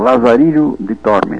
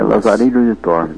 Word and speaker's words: Lazarillo de Tormes: Agora é o Lazarillo de Tormes. – Lazarillo de Tormes? – É, Lazarillo de Tormes.